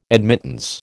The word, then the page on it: admittance: American English